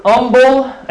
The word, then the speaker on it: humble: The joke back home, the word humble, H being silent. A friend of mine just can't pronounce the H. It's humble with a U. But the English H has a sound, so humble may be the right word there.